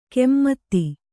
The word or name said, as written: ಕೆಮ್ಮತ್ತಿ